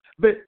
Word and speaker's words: Vayikra: Vayikra. – It's three syllables. Why? You can say why. – No, I'm not saying why nothing. – Vayikra. – Vayikra. Vayikra.